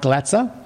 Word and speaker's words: Glatzer: I'm talking about Mike Glatzer. I have to check how to say that. I'm talking about Mike Glatzer, and I am rapt to have him here with us now.